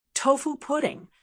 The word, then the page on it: tofu pudding: American English